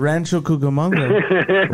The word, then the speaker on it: Rancho Cucamonga: Where's the Rancho Cucamonga? – Yeah, I'm fucking drunk. For how do you say that? – Rancho Cucamonga.